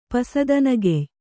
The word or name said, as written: ಪಸದನಗೆಯ್